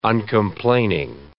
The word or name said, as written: uncomplaining